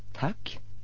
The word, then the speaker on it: tack: Tack. Спасибо. С короткой гласной А. Буква эта выглядит как и русская А, но произносится совсем иначе.